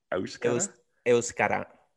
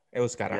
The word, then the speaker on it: Euskara: How do you say it? – Euskara. Euskara. Yeah. I might be screwing up the pronunciation, but it's Euskara.